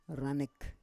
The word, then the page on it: ranek: Roon